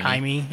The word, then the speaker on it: Jaime: Jaime. I think it's spelled like Jamie, but he's Spanish or something, so they say it's like Jaime.